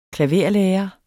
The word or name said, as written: klaverlærer